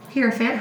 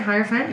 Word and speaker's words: Hierophant: Hierophant? – Yep. Hierophant, Hierophant.